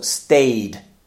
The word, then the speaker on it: stayed: And when they are voiced, you should add the ED, but you should pronounce just the D sound at the end. So stayed.